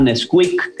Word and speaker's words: NSQK: Eh, un, otro, otro jovencillo, también habíamos invitado a un chico que se llama Nesquik, que se escribe NSQK, ¿no?